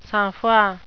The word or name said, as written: sangfroid